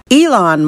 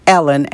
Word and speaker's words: Elon: Elon Musk, I am not pronouncing his name Ellen, as I used to call him Ellen. Ellen Musk.